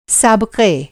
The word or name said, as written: साबक़ा